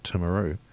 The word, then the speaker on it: Timaru: And so like Timaru and Timuka and notice that I haven't gone Māori pronunciation on Timaru because it's not a Māori word. It's an anglicised one.